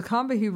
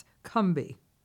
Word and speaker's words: Combahee: Well, the Combahee River, which I have recently discovered, is actually pronounced Cumbie. – Cumbie. – Not three syllables, but only two. I said we pronounce it as Northeastern, you know, black women of the 1970s would pronounce right. – it. And we were wrong. We were completely wrong.